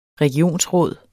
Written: regionsråd

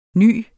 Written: ny

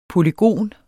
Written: polygon

